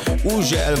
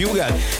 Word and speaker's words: Hugel: Így van, az úriember, akinek minden országon másképp ejtik a nevét. Van, ahol Ugel, van, ahol Hügel, van, ahol Ugel, meg mindenféle kép, de nálunk úgy mondjuk, hogy Hugel, és Salto, és a Jamaican Bam Bam érkezik, a csavarjátok fel a hangerőt.